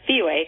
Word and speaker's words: Spiwe: It's actually pronounced Spiwe, kind of Spiwe. Like Speedway without the D.